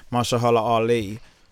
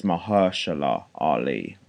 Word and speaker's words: Mahershala Ali: So the real pronunciation is Mahershala Ali. Mahershala Ali.